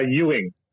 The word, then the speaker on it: Ewing: uh, Ewing, kind of like uh, Damn it. Dallas or the basketball player.